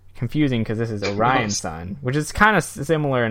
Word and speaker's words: Orion Sun: Is it Orion Sun? It's got to be this. Yeah. – Yeah, that's how you pronounce that.